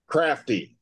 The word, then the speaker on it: Crafty: And get, spell that, what is it, Frappy? F? – Crafty. C-R-A-F-T-Y.